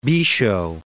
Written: Bisho

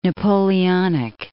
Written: Napoleonic

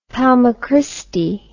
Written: palma christi

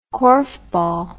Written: korfball

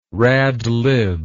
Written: radlib

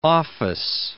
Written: office

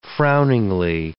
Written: frowningly

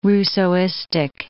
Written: Rousseauistic

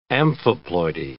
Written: amphiploidy

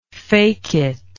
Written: fecit

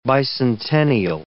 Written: bicentennial

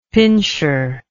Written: pinscher

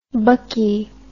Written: buckie

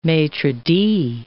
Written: maitre d'

